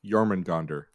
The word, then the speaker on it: Jormungandr: I'm sorry, number three, Jormungandr. – What? – Which wow, is nice. Nice pronunciation.